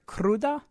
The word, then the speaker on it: cruda: In Mexico, our neighbors to the south apparently refer to a hangover feeling as, am I saying this right? Cruda? – Mm-hmm.